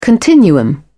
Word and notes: continuum: British English

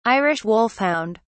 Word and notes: irish wolfhound: British English

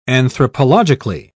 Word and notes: anthropologically: British English

